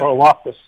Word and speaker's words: Karlaftis: He did announce today they will be without David Bell and George Karlaftis. Is that how you say it? – Karlaftis, yeah.